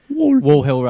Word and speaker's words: Wall Hill: And then there's a road just near my house called Wall Hill, which is like Wool Hill. – Wall, as in a wall. – Okay. – I don't know why I can't say it properly. Wall Hill Road.